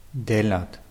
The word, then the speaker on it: делать: Делать. – Hm, die Betonung ist auf je. Делать.